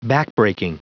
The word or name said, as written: backbreaking